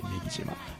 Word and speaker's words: 女木島: あの、鬼ヶ島のモデルになった女木島と、もう一個荻島って、メ木っていうのはあの女に木と書いてメ木島、